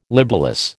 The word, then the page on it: libellous: American English